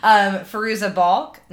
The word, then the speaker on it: Fairuza Balk: Fairuza Balk, Nev Campbell, and Rachel True.